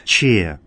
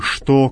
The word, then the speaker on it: что: Вот слово «что» именно так произносится в церковнославянском языке, через «че», а не «что», как в русском языке.